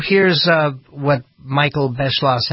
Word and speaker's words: Beschloss: But really, like Mussolini, Michael Beschloss is a, I think he says Beschloss. I should be kind to him and pronounce his name right, because I like the same courtesy.